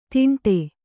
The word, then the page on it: ತಿಂಡೆ: Kannada